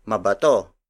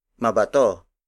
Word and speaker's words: mabato: If you add ma at the beginning of bato, it becomes mabato. Mabato mabato. Mabato is spelled as M A B A T O.